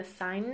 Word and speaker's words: seine: The most popular method is using a seine net. I think I'm saying that right. S-E-I-N-E. Seine, if you're German.